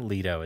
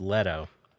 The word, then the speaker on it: Leto: So it's not Leto? – It is not Leto. It's Leto. – Oh, okay. Well, so he's Jared Leto.